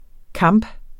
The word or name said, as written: kamp